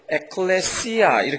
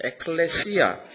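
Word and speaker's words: 에클레시아 (ἐκκλησία): 헬라 말로, 그리스 말로 교회를 에클레시아 이렇게 합니다. 발음이 C자에 들어갑니다. 에클레시아.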